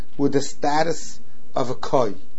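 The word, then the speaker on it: koi (כוי): So I saw from Chaim Kamievsky that the proper way to pronounce this word is koi, not kvi.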